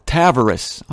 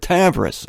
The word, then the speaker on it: Tavares: This isn't in Orlando proper, it's in Tavares, or as the locals call it, Tavares. I'm sorry to say that, but the locals pronounced it Tavares, as far as I know. T-A-V-A-R-A-S.